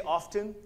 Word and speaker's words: often: I think it depends on our definition of often, which, by the way, if you say often, you're saying it incorrectly. I'm just going to throw that out there. Not in the notes, but if you pronounce the T, that is incorrect.